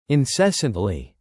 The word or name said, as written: incessantly